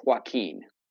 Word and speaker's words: Joaquin: They can find me on X at Tweets Joaquin. – Spell your name, please, because some people here spell it with an X. – Okay, so it's going to be at TWEETSJOAQUIN. That's JOAQUIN. Like Joaquin Phoenix.